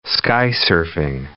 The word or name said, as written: skysurfing